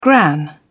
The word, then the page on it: gran: British English